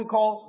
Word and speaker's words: Qal: The basic form of a Hebrew verb is what we call the call form. Can you say that word? The call form. It is spelled Q-A-L.